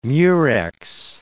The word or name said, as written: murex